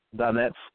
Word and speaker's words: Donetsk: And they said it's pronounced Donetsk. The citizens of D-O-N-E-T-S-K,